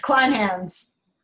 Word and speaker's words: Kleinhans: Kleinhans.